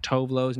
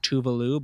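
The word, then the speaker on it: Tove Lo: When I was in Sweden, I found out that Tovlo's name is actually pronounced Tuvalu, but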